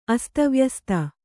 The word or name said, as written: ಅಸ್ತವ್ಯಸ್ತ